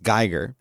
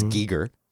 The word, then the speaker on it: Giger: But it explains all of it, but it talks about how Giger, who I've always pronounced Geiger, but apparently it's Giger. – Yeah, he's Swiss.